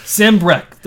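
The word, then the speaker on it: Sanbreque: Thank God for speaking it, because if you were just reading Sandbrekwoa... – We butchered the shit out of it last episode. – Sandbrek. – They say it multiple times. – Yes. – Pretty positive that's how it's said.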